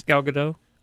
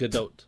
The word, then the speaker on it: Gadot: The T is pronounced, but it's Gadot. – Yeah. – So it's not Gadot, you would think a silent T, and it's Gadot. Also not Gadot. It's like Gadot. It's like it's got a little oot.